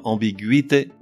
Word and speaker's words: ambiguïté: À noter que l'on prononce gu, le e restant muet, au contraire des mots qui ont la syllabe gui, g-u-i tréma ou g-u tréma i, que l'on prononce gui comme dans ambiguïté ou contiguïté, avec un tréma indifféremment placé sur le u ou sur le i.